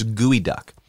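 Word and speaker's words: geoduck: So it's spelled G-E-O duck. So you'd think it's geo duck, but it's gooey duck.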